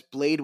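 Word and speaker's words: Blayde: And I'm Ricardo Blade Diaz. You can find me at Ricardo Blade Diaz on Instagram and TikTok. That is Blade with a Y, RICARDOBLAYDEDIAZ, just like my name is spelled.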